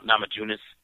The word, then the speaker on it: Namajunas: Namajunas.